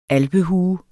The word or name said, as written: ALPEHUERNES